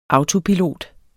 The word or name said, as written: autopilot